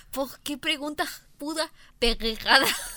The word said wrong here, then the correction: pendejada: pendejada se pronuncia normal porque no hay una R, güey.